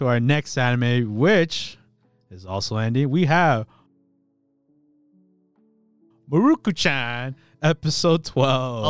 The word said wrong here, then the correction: Mieruko-chan: It's actually pronounced Mieruko-chan.